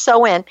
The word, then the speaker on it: Samhain: Like, so there was an ancient Celtic festival called Samhain, and it's actually spelled Samhain, but it's pronounced Samhain.